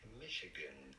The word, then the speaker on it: Michigan: Michigan. Stress on the first syllable.